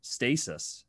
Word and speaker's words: Stasis: but you also co-founded a company called Stasis.